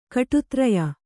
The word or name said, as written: ಕಟುತ್ರಯ